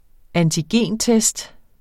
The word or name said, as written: antigentest